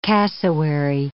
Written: cassowary

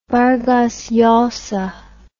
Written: vargas llosa

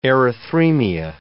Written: erythremia

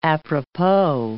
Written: apropos

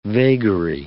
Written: vagary

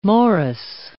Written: morris

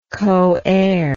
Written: coheirship

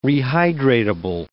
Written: rehydratable

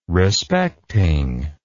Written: respecting

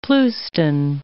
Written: pleuston

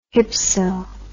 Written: hypso

